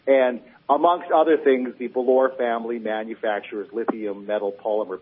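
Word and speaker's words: Bolloré: I mean, who has ever heard of the Belor family? – The what family? – The Belors. – How do you spell it? – B-O-L-L-O-R-E.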